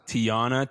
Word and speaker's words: Tyonna: Oh no, said her name wrong. But is it Tiona? – Oh no, I can't tell her that we did this now. – How do you spell it though? – T-Y-O-N-N-A.